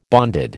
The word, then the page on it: bonded: American English